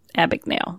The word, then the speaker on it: Abagnale: I can never pronounce his name right. Abagnale? – Abagnale. There you go.